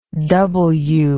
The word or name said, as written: doubleu